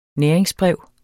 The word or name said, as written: næringsbrev